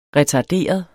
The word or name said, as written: retarderet